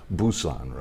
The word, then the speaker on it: Busan: I say Busan, but it's now pronounced, I guess, Busan, right? Busan. With a B. It used to be P.